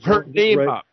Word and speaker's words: Purnima: Purnima, P-U-R-N-I-M-A, Purnima Nath.